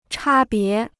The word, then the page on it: 差别: chā bié